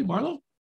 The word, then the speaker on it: Marlow: Did I say that properly, Marlo? – Yes.